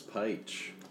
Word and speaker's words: Pych: My name is Bruce Pych. P-Y-C-H.